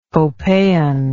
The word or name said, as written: copehan